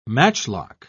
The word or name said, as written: matchlock